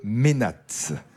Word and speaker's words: mainate: c'est le ménate. Ouais. Okay. Vous ne le saviez pas ? – Non. – Non, ça s'écrit M-A-I-N-A-T-E, le ménate ou ménate religieux.